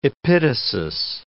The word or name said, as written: epitasis